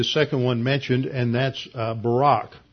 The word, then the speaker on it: Barak: In Hebrew, the accent's always on the second syllable, and so just like the prime minister former prime minister of Israel was Ehud Barak, that is how you pronounce the name here.